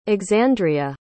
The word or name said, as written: Exandria